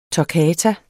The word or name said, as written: toccata